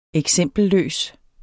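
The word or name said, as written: eksempelløs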